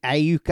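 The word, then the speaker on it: Aiyuk: One guy who stands out to me is that kid from Arizona State, and I keep forgetting how to say his name, but Brandon Ayuk, I think is how you pronounce it.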